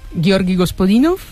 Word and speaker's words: Georgi Gospodinov: Ayúdanos a pronunciar los autores. – A ver, Georgi Gospodinov, Tsvratka Eftimova y Vera Mutavcheva.